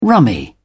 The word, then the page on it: rummy: British English